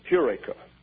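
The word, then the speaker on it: Eureka (εὕρηκα): So that is H-E-U-R-E-K-A. But the first syllable is accented, is Eureka.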